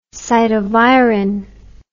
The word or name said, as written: cytovirin